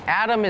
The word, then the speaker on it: atom: Atom is tricky because it sounds like there's a D in it atom But it's actually A-T-O-M, but it's still pronounced Adam.